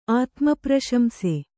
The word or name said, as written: ಆತ್ಮಪ್ರಶಂಸೆ